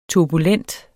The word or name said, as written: turbulent